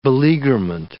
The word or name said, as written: beleaguerment